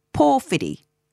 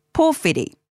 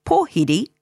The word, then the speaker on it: Pōwhiri: The O sound of pō is elongated, so Pōwhiri, Pōwhiri. In some areas, you may hear people drop the W and say Pōwhiri, but the meaning is the same.